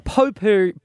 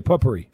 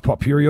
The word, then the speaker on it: potpourri: I don't think you pronounce the T. – Hey, in Penrith you do. Potpourri, buddy. Well, that's a nice pot puree on your table there, mate. Okay. No, it's not puree, it's just pot.